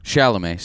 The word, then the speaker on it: Chalamet: It's got Steve Carell and Timothy Chalamet. I think that's how you say his name. – Chalamet. – Sorry. Yeah. I'm sorry, Chalamet. – Chalamet. – Yeah, Chalamet. Timothy Chalamet.